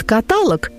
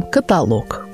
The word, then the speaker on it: каталог: Например, «Тому, кто говорит «каталог», скажу, что слог подобный «жалок». Употребив высокий слог, мы произносим «каталог».